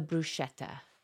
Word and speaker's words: bruschetta: Okay, my number three is the bruschetta. Is am I saying bruschetta.